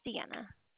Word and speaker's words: Sienna: My name's Sienna. – Can you spell it for me? – Yes, S-I-E-N-N-A.